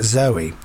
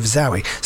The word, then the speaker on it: Zowie: This is true even if you get confused by the name of his son, Zowie Bowie, now Duncan Jones, because in that case, Zowie is pronounced like the girl's name, Zoe, and not the more expressive Zowie.